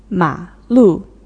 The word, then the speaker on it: 马路: 马路 the third tone and the fourth tone.